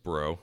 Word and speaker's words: Brault: But Mike Brault or Mike Bro, again, so I'll say the French pronunciation for the sake of, I believe that's how it's actually pronounced.